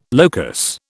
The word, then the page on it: locus: British English